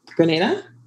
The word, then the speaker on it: Grenada: Grenada.